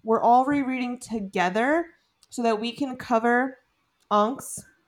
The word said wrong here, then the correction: Onyx: You always say Onks, and I'm like, what? – Onyx? – What is Onks? Onyx, yes.